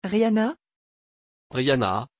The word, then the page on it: Riana: French